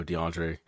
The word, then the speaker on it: DeAndre: I tell him, oh, you know, DeAndre. And then we're waiting. And then they call my name, but they're missing a syllable.